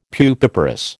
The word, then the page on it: pupiparous: American English